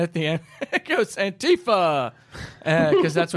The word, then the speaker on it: Antifa: Antifa. Yes, that is how you pronounce it.